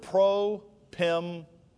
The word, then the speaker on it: propempo: That word helped is the Greek word propempo. So you would spell it like this in English. P-R-O-P-E-M-P-O. Propempo.